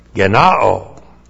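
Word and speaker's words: gennaō: Then we have the verb genao. G-E-N-N-A long O. Genao.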